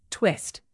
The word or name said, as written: twist